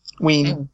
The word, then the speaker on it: Wein: And your potential answers are A, John Byrne, B, Len Wein? Vine? – Ween. – Ween. – I think it's pronounced Len Wein.